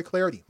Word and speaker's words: Clardy: At Troy Clardy. Last name is spelled C-L-A-R-D-Y at Troy Clardy.